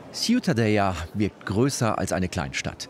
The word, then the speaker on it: Ciutadella: Aber Ciutadella, richtig ausgesprochen? – Ja, das Städtchen, Ciutadella.